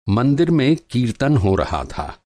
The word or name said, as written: कीर्तन